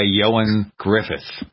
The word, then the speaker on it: Ioan Gruffudd: It's, by the way, Ioan Gruffudd, although it's spelled in Welsh. That's why it sounds like something else.